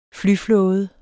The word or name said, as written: flyflåde